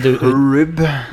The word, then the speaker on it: Hryb: H-R-Y-B. – Det er det Herb? – Ja, det skrives H-R-Y-B, men uttales H-Y-R-B. Herb.